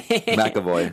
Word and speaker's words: McAvoy: McAvoy.